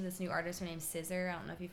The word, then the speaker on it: SZA: Asked Angelica how to pronounce that. – It's SZA.